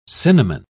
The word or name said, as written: cinnamon